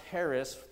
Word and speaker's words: charis: The word that they use for grace is a word that looks like it's like charis, like charismatic, C-H-A-R-I-S. It's actually pronounced Harris, the C is silent.